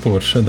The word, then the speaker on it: Porsche: Я помню, я помню, что правильно говорить Porsche. Правильно говорить Porsche, да.